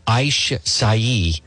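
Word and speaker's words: açaí: It is pronounced Aish Sai, a little purple fruit, but all you need is an inch.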